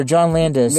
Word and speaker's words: Landis: Oh, that was the director, John Landis. – Landis.